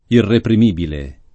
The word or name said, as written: irreprimibile